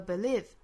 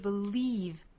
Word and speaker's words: believe: To those that, to those that, 后面 believe in it the most, believe 这个词我们注意后面是紧元音哈，不要读成了 believe, 应该是 believe, 左右嘴角要有一个用力向两边拉开的一个动作 believe in it, believe in it, 和后面两个词做连读就是 in it Vin it, believe in it, 恰好后面两个词都是松元音哈，所以我们要体会它们的区别，一个是 leave, e, 一个是 in it.